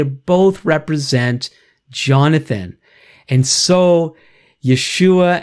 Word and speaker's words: Yeshua: It's all to say that that Jesus is Joshua, even though all the J's should be pronounced with a Y and so on. Now, why do we hear Yeshua very often? So people who want to say his name more authentically, like myself, depending in the context that I'm in, the Hebrew is often represented as Yeshua.